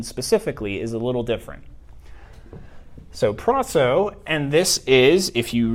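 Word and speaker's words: proso (πράσσω): So you got pi, rho, alpha, sigma, sigma, omega. Pronounced proso.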